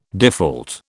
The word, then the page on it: default: British English